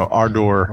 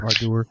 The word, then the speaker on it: Ardour: No, Ardour. Ardour. That's how I pronounce it.